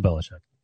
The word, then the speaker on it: Belichick: How do you say Belichick? – Bill Belichick.